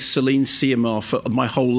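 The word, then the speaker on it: Céline Sciamma: It's Celine Siamar.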